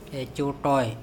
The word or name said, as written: ecoToe